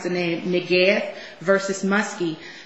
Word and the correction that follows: Mukasey: the issue there... – That's Mukasey.